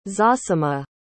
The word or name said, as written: zosima